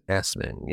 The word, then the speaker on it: Assmann: And finally, we have the most infamous suspect of all, Hans Osman. Yes, that's his... Excuse me. Hans Assmann. Yes, that's his real name, and yes, it's pronounced exactly how you think it is.